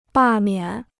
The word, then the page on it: 罢免: bà miǎn